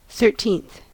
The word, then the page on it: thirteenth: American English